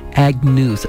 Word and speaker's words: Agnus: I just looked it up and said it wrong, but Agnus. It looks like Agnes, but it's not.